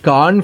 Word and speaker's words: Cannes: If you're having issues pronouncing the word Khan from Cannes Film Festival and, you know, you just can't seem to get it right, then looks like you have company.